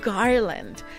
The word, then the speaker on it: garland: Así que eso es un garland. Garland se escribe G-A-R-L-A-N-D. G-A-R-L-A-N-D.